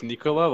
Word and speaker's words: Nikola: I assume it's Nikola, like the person.